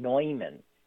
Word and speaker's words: Neumann: Those of us in Philadelphia are very aware that the St. Newman that we have is pronounced St. John Neumann, and he was the third or the fourth bishop here of Philadelphia.